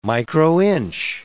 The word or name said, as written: microinch